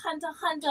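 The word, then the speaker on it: Hunter x Hunter: I was like, oh, no, you don't pronounce the X. It's just called Hunter Hunter.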